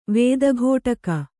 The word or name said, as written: ವೇದಘೋಟಕ